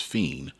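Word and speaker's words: FE!N: It's pronounced Fiend by Travis Scott featuring Playboi Carti.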